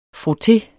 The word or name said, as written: frotté